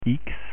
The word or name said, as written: x